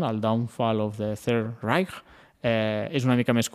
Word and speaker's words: Reich: Reich? Reich. Reich. El Marc ho pronuncia millor. Reich.